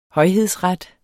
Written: højhedsret

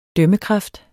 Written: dømmekraft

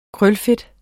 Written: krølfedt

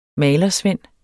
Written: MALERSVEND

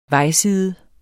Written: vejside

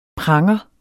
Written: PRANGERE

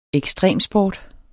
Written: EKSTREMSPORT

